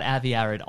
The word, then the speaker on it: Avi Arad: I know nothing about Avi Arad. Honestly, I don't even know how to pronounce his name correctly. – No, you're nailing it.